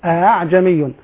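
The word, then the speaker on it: ءأعجمي: كذلك الهمزة المسهلة، الهمزة المسهلة من الحروف الفرعية، الهمزة المسهلة قرأ بها حفص في قوله تعالى أعجمي، لاحظ لا أقول أعجمي، لا أقول آآ ولا أقول آآ، لا أنطق ألفا ولا أنطق همزة محققة، وإنما الهمزة بين الألف و الهمزة بينها وبين الألف أعجمي.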